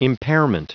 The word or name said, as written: impairment